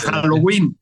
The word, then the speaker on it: Halloween: Hasta ya lo mexicanizamos. J-A-L-S. Halloween. Yo diría que con acento en la I. – En la I, sí, claro.